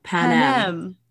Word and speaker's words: Panem: I decided to dig a little deeper into Panem? How do you pronounce this? Help me. – Panem. Yes.